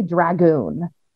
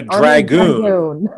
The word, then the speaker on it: dragoon: A dragoon. Yeah. Is that how you pronounce dragon? – No, you gotta add an O.